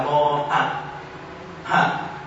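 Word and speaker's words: moha: Chữ này đọc là mô hạnh, mô la chỉ tật la, đọc là si căn tâm.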